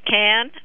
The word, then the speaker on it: Cannes: If you talk to any of the French people, they will tell you absolutely it's Cannes, like a soup can. So all of us who spend our life here in the United States talking about Cannes, we are pronouncing it incorrectly.